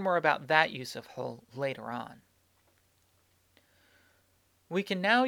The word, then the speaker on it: hal: This pronoun, in fact, has several different pronunciations depending on the speaker. Some say hul, some say ul, some say l, and some say n. We'll use the spelling H-A-L, hul, here in this book, but you should be aware of these other pronunciations and know that they are all correct.